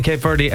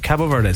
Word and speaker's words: Cabo Verde: One thing I'm confused about, and like, I don't know why you haven't just gone on the Cabo Verde train. – Oh yeah. – What's the story? Why are we saying Cape Verde? And then you could hear Hugh in the commentary there saying Cabo Verde.